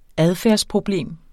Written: adfærdsproblem

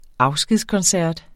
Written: afskedskoncert

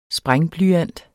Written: sprængblyant

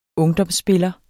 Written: ungdomsspiller

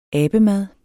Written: abemad

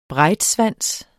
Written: breitschwanz

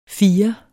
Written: firer

